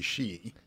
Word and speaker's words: Shia: Well, it's probably better to say she. – She. – Actually, when you say she, there's the sound in there that you probably can't do, and I wouldn't be able to teach you within the next 20 minutes or so.